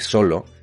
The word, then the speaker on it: solo: Entonces se entiende ya que solo Lleva la, el golpe fuerte de voz, lleva la sílaba tónica en la primera sílaba en so.